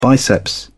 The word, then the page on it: biceps: British English